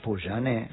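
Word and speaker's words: Pouyanné: Poujane, sí.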